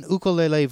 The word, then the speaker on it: ukulele: Please, stop, white people, make an ukulele versions. And yes, that's the correct pronunciation, because I am here in Hawaii. Ukulele.